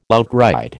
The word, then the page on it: outride: American English